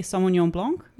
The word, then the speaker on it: Sauvignon Blanc: I would say Sauvignon Blanc.